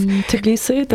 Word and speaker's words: Tbilisi: Tbilisi, the Bassiani in yes.